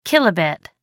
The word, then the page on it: kilobit: American English